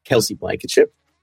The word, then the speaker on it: Kelcye Blankenship: I'm your host, the CEO at Fin, and I am joined by the wonderful Kelsey Blankenship, Channel Engagement Manager at CyberQP.